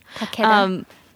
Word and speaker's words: Takeda: Monstrous by Marjorie Liu and Sana Takeda, maybe is how you say it? – Takeda.